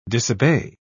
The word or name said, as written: disobey